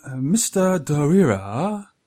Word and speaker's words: Mr. Driller: Well, I've been playing Mr. Dorira with the wife. That's how you pronounce it.